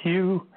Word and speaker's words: Hugh: My name is Hugh, H-U-G-H. The last name, Trawlson, is spelled T like Tom, R-A-U-L, S like Sam, E like Edward, N like Nancy, and after my name, just put And Maya Angelou.